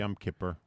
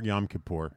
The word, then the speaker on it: Yom Kippur: How do I pronounce that properly? – Yom Kippur. – Yom Kippur. – Or Yom Kippur. – Okay. – Either one, both.